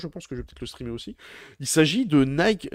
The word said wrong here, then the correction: Nightingale: Euh, euh, ah, Nightingale. Nightingale, merci. J'arrivais pas du tout à le prononcer.